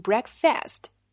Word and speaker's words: breakfast: 注意了，这个词既不能读成 breakfast，又不是 breakfast。注意一下，这里是没有儿化音的。如果你读成 first，就不够精准了，而是 first。